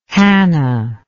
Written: hannah

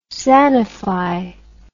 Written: sanify

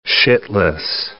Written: shitless

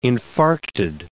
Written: infarcted

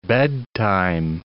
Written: bedtime

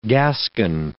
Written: gascon

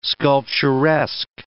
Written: sculpturesque

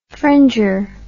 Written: fringer

